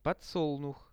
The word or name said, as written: podsolnuh